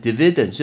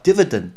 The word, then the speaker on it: dividend: dividend 这个字 dividend 很多人会念成 dividend 是不对的，叫 dividend dividend 就有点像是分开。